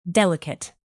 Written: delicate

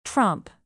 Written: tromp

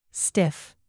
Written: stiff

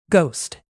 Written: ghost

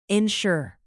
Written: ensure

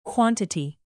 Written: quantity

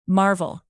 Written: marvel

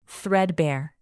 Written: threadbare

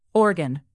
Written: organ

